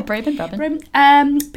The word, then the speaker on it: Brabin: Yeah, Brabin.